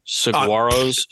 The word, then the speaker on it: Saguaros: And the G is silent. The Surprise Saguaros. Saguaros, Saguaros, the Cactus.